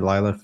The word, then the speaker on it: Lyla: Is it Lila? I think so. – That sounds right. L Y L A Lila. That's how you pronounce it.